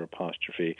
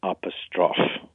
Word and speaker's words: apostrophe: said that it should be pronounced as the French pronounce it, which is apostrophe, and three syllables rather than four, with the emphasis on the final syllable.